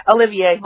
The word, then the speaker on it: Olivier: How do you say your last name? Olivier? – Yeah, Olivier, like Lawrence.